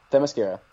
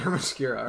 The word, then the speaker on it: Themyscira: Themyscira. – That's it. – Themyscira, right.